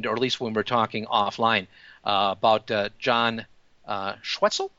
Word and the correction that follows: Schweckel: I believe that's pronounced? – Schweckel. – Like Schweckel. – Like Freckle.